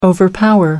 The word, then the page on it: overpower: American English